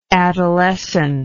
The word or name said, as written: adolescently